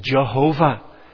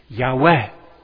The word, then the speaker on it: Yahweh: The proper way to say this in the Hebrew is Yahweh.